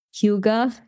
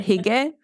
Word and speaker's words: Hygge: I have another example with a carry from Chalet Hygge. It's really hard to pronounce that word, Hige, Hygge.